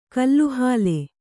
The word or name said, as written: ಕಲ್ಲುಹಾಲೆ